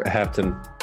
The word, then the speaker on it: Hepton: Russell, the trail hunter, Hepton.